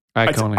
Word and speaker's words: Iacone: This was written by Jeremy, I want to make sure I get the name right, Iacone. – Iacone. – Iacone.